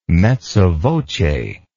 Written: mezza voce

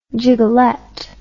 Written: gigolette

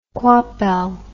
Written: coypel

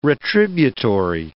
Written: retributory

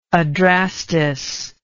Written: adrastus

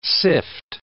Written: sift

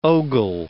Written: ogle

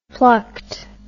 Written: plucked

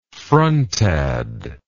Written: frontad

